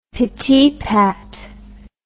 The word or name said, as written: pittypat